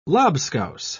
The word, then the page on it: lobscouse: British English